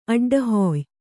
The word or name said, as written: ಅಡ್ಡಹಾಯ್